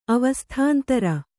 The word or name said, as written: ಅವಸ್ಥಾಂತರ